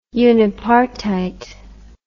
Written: unipartite

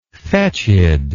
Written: fetched

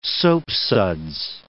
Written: soapsuds

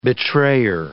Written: betrayer

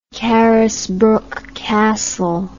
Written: carisbrooke castle

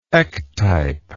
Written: ectypal

